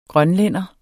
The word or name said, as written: grønlænder